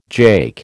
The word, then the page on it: jake: American English